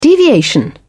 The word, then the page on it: deviation: British English